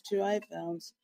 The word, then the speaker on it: 2i Films: So my company is called 2i Films, like I, but it's spelled with an I, with a letter I, but it's 2i Films.